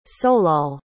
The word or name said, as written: solal